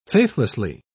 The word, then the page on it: faithlessly: British English